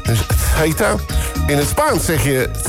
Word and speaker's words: Ceuta: Hoe spreek je Ceuta uit? Daar gaan we dan. Oh, heb ik het allemaal weer verkeerd? Oh nee, kijk, daar hebben we het. In het Spaans zeg je... Ceuta. Ceuta.